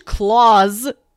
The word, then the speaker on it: Klaus: I'm going to keep pronouncing it like, you know, the German name and not Santa Claus. So we can watch Klaus Scrooged.